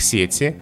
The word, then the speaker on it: сеть: В словарях нормы такие. Если мы говорим о косвенных падежах, то на первом слоге нет сети. Подключиться к чему? К сети. И только в предложенном падеже допустимы варианты «в сети» и «в сети». А во множественном числе ударение на окончание «сетей», «сетями», «сетях» там сложности нет.